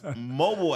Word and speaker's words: Mobile: But it's Mobile, Alabama. It's spelled M-O-B-I-L-E. – Correct. – But they say Mobile, Alabama versus uh-huh.